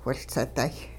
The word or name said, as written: whiLseday